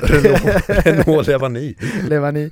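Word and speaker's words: Renaud Lavillenie: Nu kanske jag uttalar hans namn lite fel här, men Renaud Levallée. Hjälp mig. – Renaud Levani.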